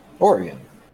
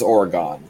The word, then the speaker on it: Oregon: Wait, what's the right pronunciation of Oregon? – Oregon. No one says Oregon.